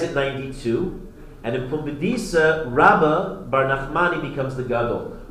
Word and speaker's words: Rabba: The first, Rabba, is with a base. Rabba versus Rava. Rava with a vase,